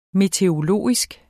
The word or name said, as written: meteorologisk